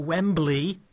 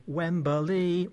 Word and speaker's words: Wembley: Wembley, or as football fans would say, Wembley, because they always put in an extra syllable.